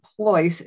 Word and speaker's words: Pleuss: My last name, Ploys, is spelled P-L-E-U-S-S, as in Sam, Sam.